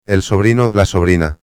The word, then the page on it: el sobrino-a: espanhol